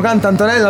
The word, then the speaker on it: CantAntonella: Un ringraziamento a Max Luciani, a tutto lo staff che collabora, a tutti gli artisti, all'Agrimusicismo CantAntonella, l'ho detto giusto.